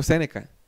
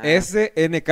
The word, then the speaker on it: SNK: No, es SNK. SNK es por los juegos de SNK.